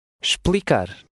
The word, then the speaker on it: explicar: The initial E is silent and the X has a sh sound. – Explicar.